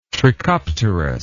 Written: trichopterous

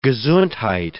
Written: gesundheit

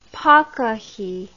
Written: pakahi

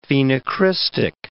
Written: phenocrystic

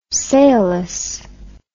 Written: sailless

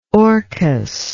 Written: Orcus

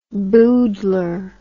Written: boodler

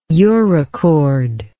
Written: urochord

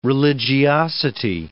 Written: religiosity

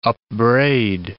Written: upbraid

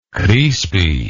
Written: Crispi

